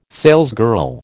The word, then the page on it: salesgirl: American English